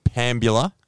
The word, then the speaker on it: Pambula: Now, the social seg, as always, Deeble, is sponsored by Wheeler's Oyster Farm at 162 Arthur Kane Drive, Pambula, New South Wales. Did I say that correctly? – You did, absolutely.